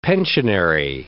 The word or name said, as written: pensionary